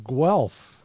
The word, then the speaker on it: Guelph: You're also a professor and animal behaviorist at the University of Guelph.